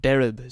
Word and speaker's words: derb: This next one by the same man, the derb, as it is called in County Leitrim, but derib is how it's pronounced, I'd derib.